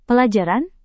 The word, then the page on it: pelajaran: Indonesian